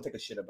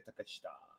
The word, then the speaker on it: Takeshita: Everybody call Takeshita, but it's Konosuke Takeshita. – Takeshita.